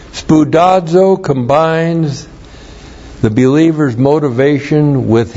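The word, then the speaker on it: Spoudazo (σπουδάζω): Spoudazo. S-P-O-U-D-A-Z, long O. Spoudazo.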